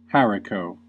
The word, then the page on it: haricot: American English